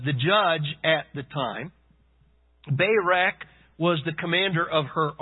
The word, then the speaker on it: Barak: It is pronounced Barak. I did look it up.